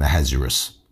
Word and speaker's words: Ahasuerus: Now it came to pass in the days of Ahasuerus, and there are multiple ways to pronounce his name. Some people call him Ahasuerus, Ahasuerus.